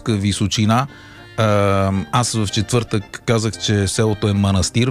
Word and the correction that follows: Манастир: Веднага Михаил Делирадев ме контрира с: абе, знаеш ли, че ударението е манастир? – Ами това е като винарска изба Драгомир.